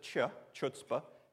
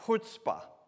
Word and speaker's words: chutzpah: Okay, don't try and pronounce it chutzpah, because it's not. It's chutzpah. And if you are a good Hebrew or good Jew, you would Try and pronounce it from the back of your throat.